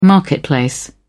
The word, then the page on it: marketplace: British English